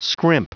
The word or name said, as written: scrimp